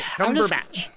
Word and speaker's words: Cumberbatch: Cumberbatch. Cumberbatch. That's how you say it.